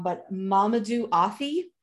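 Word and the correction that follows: Athie: Ati, it's silent H.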